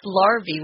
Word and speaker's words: larvae: Larvae here is the plural form of larva, and larvae, it's pronounced larvae, which is kind of strange. I know it looks like larvae, but it's larvae.